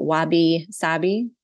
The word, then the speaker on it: wabi-sabi: but wabi-sabi.